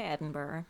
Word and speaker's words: Edinburgh: I would say Edinburgh. – Edinburgh? Mm-hmm. So like no G at all. – Okay, Edinburgh.